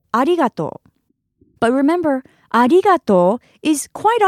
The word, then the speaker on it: arigato: In Japanese, thank you is arigato.